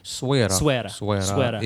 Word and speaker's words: Essaouira: Am I pronouncing it correctly? – Essaouira is in the right way to pronounce Essaouira. – It. Essaouira is because of the French influence.